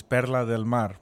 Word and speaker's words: Perla del Mar: Since we have the budget for it now, the pronunciation for PDM is Perla del Mar, which means Pearl of the Sea.